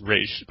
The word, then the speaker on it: Ra's: And then Terry walks in on them talking, and he's all telling him, he's calling Ra's Ra's al Ghul, and then Talia corrects him, saying, it's actually pronounced Ra's, but it's a common mistake. And then you actually hear Danny O'Neill always call him Ra's, and since he created him, that's the correct name, so.